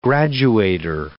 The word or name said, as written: graduator